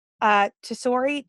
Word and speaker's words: Tesori: Tessori, T-E-S-O-R-I.